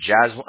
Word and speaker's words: Jazwan: But it's Jazwan? – It's Jazwan. Ah, you said it right. Yeah, Jazwan John Hill. – Okay. – Mm-hmm. So if you see emails like Jazwan John Hill or John, or they say J-A-Z Hill, those are also me as well.